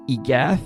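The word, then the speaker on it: Y Gath: A good place to start is one of the few songs that is not in English, Egath, which is spelled capital Y, capital G-A-T-H, which means the cat in Welsh.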